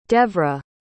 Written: devra

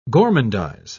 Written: gormandize

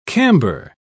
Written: camber

